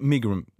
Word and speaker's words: megrim: Habe ich es jetzt schon mal richtig ausgesprochen? – Ja? Ich gehe davon. Migrim. – Migrim. – Migrim. Migrim. – Gut, korrigieren wir das noch.